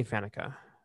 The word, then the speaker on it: Faneca: Give me Faneca over Boselli. – Excellent. You said it right this time too. So I did. During our opener, I kept mispronouncing it.